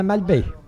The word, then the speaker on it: Malbaie: Did I say that right? – Galop de Malbaie. Malbaie. – Malbaie, that's the name of a town we have in Quebec province.